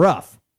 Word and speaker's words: Roof: Now, they're pronouncing his name rough. – Yes. – R-O-O-F. I don't know. Looks like roof to me.